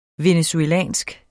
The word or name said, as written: venezuelsk